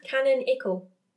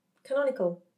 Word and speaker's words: canonical: Or think of tiny canon. Canonical. Canonical. – Tiny canon. Canonical.